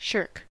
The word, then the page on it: shirk: American English